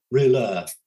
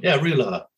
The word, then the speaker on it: Rouleur: I pronounce it Roller. – A harder L, Roller. – Roller. – Roller. Yeah, I find that Americans struggle with the pronunciation more than most. I'll probably sort of go a bit too over the top, but Roller. Yeah, we go with that.